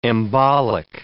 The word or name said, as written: embolic